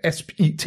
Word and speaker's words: ASPIT: Først og fremmest, kan du ikke prøve at forklare lidt, hvad ASP-IT det er? – Jo, og så lad os starte med at udtale det, som ja. Det skal, nemlig ASPIT.